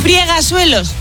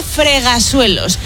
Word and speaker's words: fregasuelos: Friegasuelos, no se dice. Se dice fregasuelos.